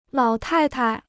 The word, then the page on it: 老太太: lǎo tài tai